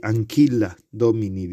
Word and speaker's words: Ancilla Domini: Y nosotros estamos leyendo, mm, comentados, ¿verdad?, algunos textos de Adrien von Speyer, del, libro, de la obra Ancilla Domini, Anquila Domini, diríamos ahora, ¿verdad?, en esta forma clásica de pronunciación del latín